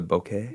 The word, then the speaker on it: bokeh: Like, you know how it does that, like, I don't know how to pronounce it. B-O-K-E-H. – Oh, the bokeh? – Yeah, the bokeh.